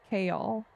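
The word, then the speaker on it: Chaol: They pronounce it Kaol. – Kaol.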